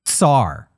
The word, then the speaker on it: Tsar: Now, do you pronounce Tsar with a C or with a T? I prefer Tsar. The T. Tsar.